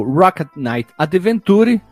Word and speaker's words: Rocket Knight Adventures: Então o jogo Rocket Knight Adventure, que é a pronúncia certa, ficou exclusiva para o Mega Driver.